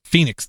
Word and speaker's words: Fenix: Fenix? – Phoenix, damn it. – What is it? – Phoenix. It's spelled F-E-N-I-X. I always call it Fenix, so I remember how it's spelled.